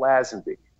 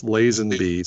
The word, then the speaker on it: Lazenby: As with respect to Paul Lazenby, okay. Now, Paul Lazenby. – Technically, it's Lazenby. So by you consistently mispronouncing his name, that's more heat.